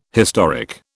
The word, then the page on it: historic: British English